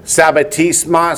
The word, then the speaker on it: Sabbatismos: That's Sabbatismos, spell it S-A-B-B-A-T-I-S-M-O-S.